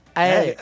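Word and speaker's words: Éire: E, aliás, a pronúncia é Eire, tá? – Eire.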